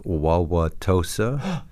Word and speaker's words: Wauwatosa: I'm from a place called Wauwatosa. – Yes. – Can you spell that? – Wauwatosa, Wauwatosa.